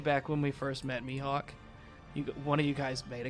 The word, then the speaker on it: Mihawk: And every time he said my hawk, my brain screamed, it's pronounced Mihawk.